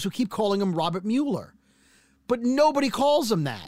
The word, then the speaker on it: Mueller: He spells his name M-U-E-L-L-E-R. Now, some people might pronounce that Mueller, but it's Mueller.